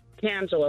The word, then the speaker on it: Tangela: Is it Tanjala? – Tangela, like Angela, but with a T.